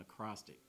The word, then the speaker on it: acrostic: And I'm going to do an acrostic. Is that how you say it? Acrostic? – Yes. – Okay. Should be.